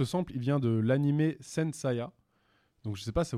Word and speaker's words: Saint Seiya: T'as mal prononcé, c'est pas Saint Seiya, c'est Saint Seiya. – Saint Seiya, ouais.